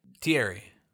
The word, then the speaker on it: Thierry: Thierry? – Yeah, that's most likely French. – Oh. – I think when they pronounce it, it's like, like it's like Terry.